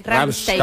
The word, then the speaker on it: Rammstein: Rammstein.